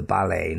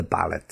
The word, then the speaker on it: ballet: Now, just in case you didn't know this, words that have an origin in French and end with the letter T also have a silent T sound. For example, the ballet, not the ballet, the ballet. Gourmet cooking, not gourmet, gourmet cooking. Rapport, the rapport with his origins, the connection with his origin, rapport.